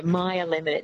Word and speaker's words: Meyer: So maybe a Meyer lemon, so M-E-Y-E-R. – Yeah. That's it, yes. Excuse my bad pronunciation of that. – Oh, good. It's that, yeah, Meyer lemon.